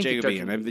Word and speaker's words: Jacobean: I think Jacobean? I don't know the correct pronunciation. – I believe it is I think Jacobean. You're right the first time.